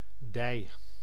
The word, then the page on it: thy: /ðaɪ/; [ðaɪ]